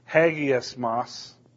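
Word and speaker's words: hagiasmos (ἁγιασμός): It's pronounced actually hagiasmos.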